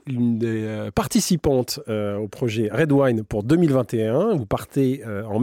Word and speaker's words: Raid: Alors, Red Wine, ça s'écrit R-A-I-D, évidemment.